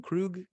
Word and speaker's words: Kruge: Kruge, Christopher Kruge.